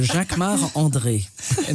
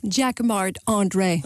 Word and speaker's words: Jacquemart-André: Jacques Or no, you say it like André. A Frenchman. – Jacques-Marc of course, André. Because I'm French. So Jacques-Marc André.